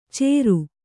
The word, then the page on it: ಚೇರು: cēru